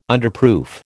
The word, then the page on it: Underproof: American English